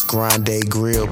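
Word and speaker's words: Grande Grille: As far as meal prep goes, you can also find me on IG at Grande Grill, and the spell that is GRANDEGRILLE. That's Grande Grill, pronounced Grande Grill.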